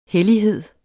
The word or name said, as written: hellighed